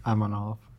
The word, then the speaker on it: Amanov: How do you say your last name? – Aminov. – Aminov? Yeah. I did it right.